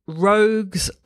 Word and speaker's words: Rogz: And it is called the Rogue's Lighthouse, and you spell that R-O-G-Z, or if you're in North America, R-O-G-Z, Lighthouse.